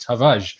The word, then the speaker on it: Tavares: You don't actually pronounce the, the R in a hard way, it's just Tavares, um, it seems.